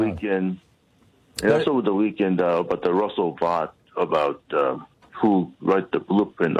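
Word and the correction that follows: Vought: Yeah, it's pronounced vote, but yes, absolutely.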